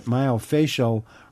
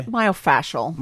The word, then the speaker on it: myofascial: myofascial really helped her. Is that the proper pronunciation, by the way? – Myofascial. – Myofascial. Okay.